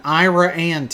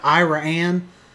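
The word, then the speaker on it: Iraan: So number two is Denny the Dino, or Denny the Dinosaur, in Ira Ann, Texas. That's right, I said Ira Ann. It is spelled like Iran the country, but with an extra A, and they call it Ira Ann.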